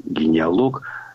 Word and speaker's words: генеалог: Ударение надо ставить исключительно на последний слог. Генеалог. И это какого-то рода исключение из других схожих слов.